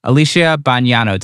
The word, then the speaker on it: Alicia Bognanno: Let's please welcome Alicia Bognanno to the podcast.